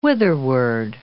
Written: whitherward

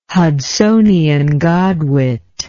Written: Hudsonian godwit

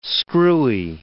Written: screwy

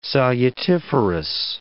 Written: salutiferous

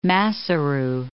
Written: Maseru

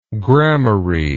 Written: gramarye

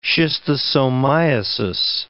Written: schistosomiasis

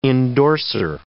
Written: endorser

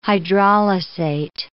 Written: hydrolysate

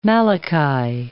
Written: Malachi

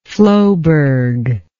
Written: floeberg

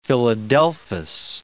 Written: philadelphus

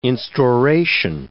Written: instauration

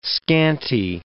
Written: scanty

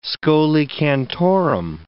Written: scholae cantorum

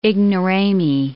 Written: ignorami

